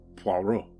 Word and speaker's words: Poirot: Okay, Mr. Perot, which way? Laura asked. – You can call me Hercule, Daniel said, imitating the Belgian detective, and the name is pronounced Poirot.